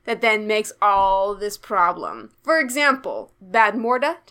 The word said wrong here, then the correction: Bav Morda: Did I say that right? – Bav Morda.